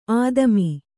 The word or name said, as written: ಆದಿಮ